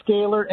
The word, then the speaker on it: Scalar: Hey, and you are from, now I don't know how to say this, is it Scalar? Scalar? How do you say this? – Scalar Energy, very good.